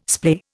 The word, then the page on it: splay: Indian